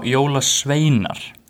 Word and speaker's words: Jólasveinar: So after telling you everything about Icelandic Christmas traditions last week, we are finally going into the Jóla Sveinir. Did I say that right? – Jóla Sveinar. – Sveinar.